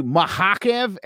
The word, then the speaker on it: Makhachev: And thinking about, and by the way, Makhachev, as I call him, if you watch when he was fighting in Russia before he got to the MMA, that's actually how you pronounce it. That's the actual, like, Dagestani. Everybody was correcting me last week, and I do appreciate it, but that is actually how you would say it in Dagestani.